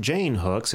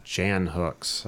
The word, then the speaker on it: Jan Hooks: When we reported on her death, I was saying her name wrong. It is not Jane Hooks. It is, in fact, Jan Hooks.